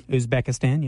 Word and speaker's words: Uzbekistan: Uzbekistan? – Uzbekistan, yeah.